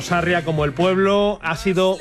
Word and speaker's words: Sarria: Es que no sé si es Sarriá, Sarria, bueno, porque como no lleva tilde, me tienen confundido, fíjate. – Por favor, que alguien nos mande un audio y nos lo aclare. – Bueno, alguien con buen conocimiento me dicen que es Sarria, no Sarriá.